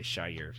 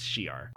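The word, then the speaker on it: Shi'ar: No, I was going to say Shire because that's how I always just pronounce it, but it's Shiar.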